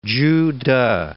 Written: Chu Teh

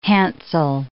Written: handsel

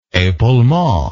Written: epaulement

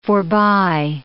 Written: forby

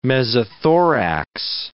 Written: mesothorax

